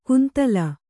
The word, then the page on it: ಕುಂತಲ: kuntala